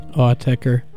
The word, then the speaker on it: Autechre: Autechre.